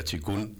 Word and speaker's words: Qigong: El Tai Chi tiene 5 áreas y una de ellas es el Qigong, que Qigong, que se pronuncia Qigong,